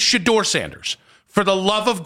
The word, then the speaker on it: Shedeur: It's not hard. It's Shador Sanders.